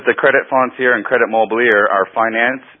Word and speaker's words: foncier: And I opened up my Encyclopedia Britannica, 1903, and I turned to look for credit mobiliere, and I find another word, credit foncier, I believe it is the pronunciation. – Okay, foncier, yes, okay.